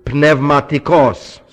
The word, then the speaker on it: pneumatikos: No silent letters in Greek. All letters are pronounced. This would be pneumatikos. See? Pneumatikos. Pneumatikos. See? Not pneumatikos.